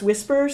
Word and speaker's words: WSPR: So in radio, there's this thing called whisper networks, WSPR, which for weak signal propagation report, but it's pronounced whisper.